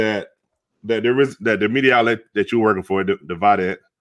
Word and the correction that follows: Vidette: The Vidette? – The Vidette. I'm sorry. Mm-hmm. I said it wrong.